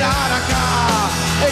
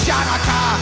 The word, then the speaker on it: Hanukkah: It's time to celebrate Hanukkah. It's not pronounced Chanukah. The sea is silent in Hanukkah.